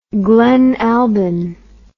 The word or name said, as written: glen albyn